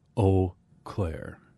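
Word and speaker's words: Eaux Claires: I can tell you the official pronunciation is Eau Claire. – All right. – Just the same as if the X and the S were not there.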